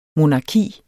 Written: monarki

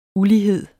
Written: ULIGHEDEN

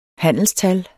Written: handelstal

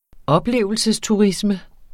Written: oplevelsesturisme

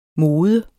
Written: moden

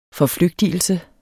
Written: forflygtigelse

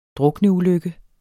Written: drukneulykke

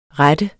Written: rette

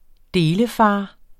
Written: delefar

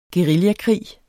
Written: guerillakrig

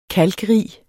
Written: kalkrig

